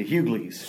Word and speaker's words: Hughleys: You actually hear the G, The Hughleys. – Hughleys, see? Because yes. – I missed that. I wasn't watching TV when that came out. The Hughleys, and according to Jim, Shake It Up, and Pair of Kings.